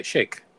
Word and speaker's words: Shayk: How would you say that? – Shayk. Shake. – Shake? – Shake. – Shayak? – Shake. – Okay. – Shay with a K on the end.